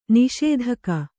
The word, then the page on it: ನಿಷೇಧಕ: niṣēdhaka